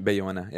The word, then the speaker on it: Bayona: Bayana? – Uh, where uh, he's from Spain. Bayona, yeah.